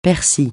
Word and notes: persil: francais

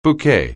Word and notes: bouquet: anglais

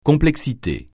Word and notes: complexité: francais

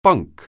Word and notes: bunk: anglais

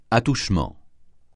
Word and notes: attouchement: francais

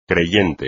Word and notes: creyente: espagnol

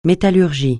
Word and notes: métallurgie: francais

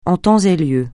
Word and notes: en temps et lieu: français

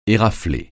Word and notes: érafler: français

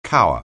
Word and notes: cower: anglais

